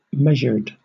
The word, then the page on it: measured: British English